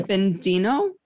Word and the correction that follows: Aurobindo: Bendino? – Sri Aurobindo. – Aurobindo. Okay, Sri Aurobindo.